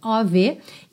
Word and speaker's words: of: Então, lembrando que esse of, of, sempre vai ser pronunciado ov.